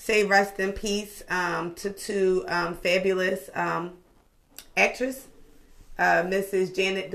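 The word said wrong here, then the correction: Ja'net: Du Bois passed away. – Janae Du Bois. – I'm sorry, Janae.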